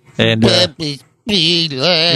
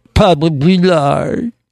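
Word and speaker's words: Papa's Pilar: You're supposed to say it like that, just like when you say, we have Pusher's Rum. It's Pusher's! – Pusher's! – Yeah, and then when you have Papa Pilar's Rum, it's Papa Bilar.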